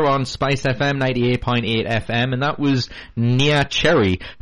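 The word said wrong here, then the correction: Neneh: Nina. – How do you say it? Nina? Oh, is it Nina? Just spelled weird.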